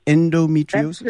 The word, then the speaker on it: endometriosis: Am I pronouncing it properly? Endometriosis? – Absolutely, absolutely.